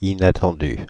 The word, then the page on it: inattendu: France; [i.na.tɑ̃.dy]; Paris